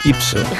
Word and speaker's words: ipso: Te lo juro, no te preocupes, que te cuento que vengo insofacto, ¿vale? Que sabes que quiere decir en griego. Ipso. – ¿Eh? Imson no se dice Inso, se dice Ipso.